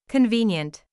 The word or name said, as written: convenient